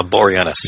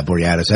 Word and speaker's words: Boreanaz: Boreanaz. – Yeah, Boreanaz.